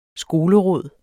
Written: skoleråd

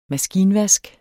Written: maskinvask

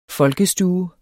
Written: folkestue

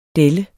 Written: delle

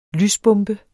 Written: lysbombe

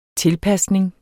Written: tilpasning

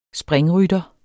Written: springrytter

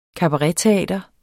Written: kabaretteater

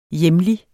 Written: hjemlig

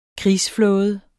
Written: krigsflåde

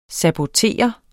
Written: sabotere

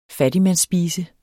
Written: fattigmandsspise

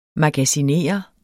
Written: magasinere

